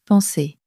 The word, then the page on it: penser: french